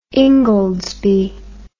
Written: ingoldsby

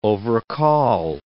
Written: overcall

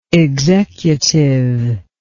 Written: executiveness

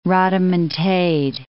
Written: rhodomontade

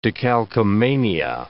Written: decalcomania

